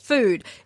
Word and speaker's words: FUD: It is spelt F-U-D. And every year we have a conversation about is it pronounced FUD or is it pronounced food? I can tell you, I hopped onto their website. I had a look. It's pronounced food.